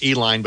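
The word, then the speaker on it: Uihlein: And, you know, like you mentioned, those billionaires, the, you know, Diane Hendricks, the Uline family, Uline, I might be getting the pronunciation wrong, but they call the company Uline because it looks like it. Name is actually pronounced Eline, but it's pretty acceptable if, you know, people understand if you go either way, which is actually it's tied to the old Schlitz brewery.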